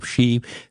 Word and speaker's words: Xi: Xi Jinping, who is the Chinese president, I think you pronounce it Xi.